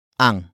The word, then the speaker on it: ang: Up next, we have ang. Ang. Ang. Ang is spelled as A-N-G.